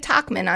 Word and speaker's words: Tauchman: Mike Tauchman, on the other hand, did I say that right? – Sure. Let's go with that.